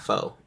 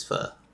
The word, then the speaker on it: pho: That's how I pronounce it. It's not pho. It's pho. Yep, pho.